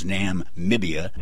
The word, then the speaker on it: Namibia: I had been pronouncing it Nambia, which is wrong. It's Namibia.